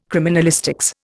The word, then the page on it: criminalistics: Indian